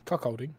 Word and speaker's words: Kirkcaldy: I think this is pronounced Kirkcaldy, so, yeah. – Kirkcaldy.